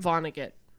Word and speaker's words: Vonnegut: Vonnegut.